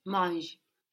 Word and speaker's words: mange: So we just pronounce it without the ER ending. We write it MANGE to make sure that we pronounce that G. No? But to the ear, it's manger without the e ending.